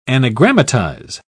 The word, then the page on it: anagrammatize: British English